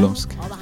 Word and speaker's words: Lumsk: Quarto bloco, uma banda chamada Lumsk. É isso? Será que é assim que fala? É assim Sim. – mesmo, né? Lumsk.